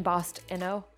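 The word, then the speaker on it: BostInno: Kind of like innovation, Bostino. – Bostino. Okay, Bostino. Yeah, okay, perfect. So now you know, listeners, in case you never said it out loud. Bostino, yeah, okay, cool.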